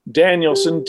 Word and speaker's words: Danielsen: And my name is spelled Rick, R-I-K, no C in that, Danielson, D-A-N-I-E-L-S-E-N.